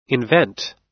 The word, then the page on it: invent: American English